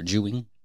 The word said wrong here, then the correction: Wu Jing: Wu Jing. I said it wrong twice.